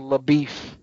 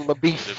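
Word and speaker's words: LaBoeuf: plays a Texas Ranger named LaBeef. But it's spelled almost like Shia LaBeouf, but they pronounce it LaBeef.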